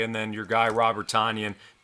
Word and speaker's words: Tonyan: Well, you must have missed the news because it's Tonyan. Apparently he has corrected the press after in his third season. The pronunciation from his last name is Tonyan. So like onion.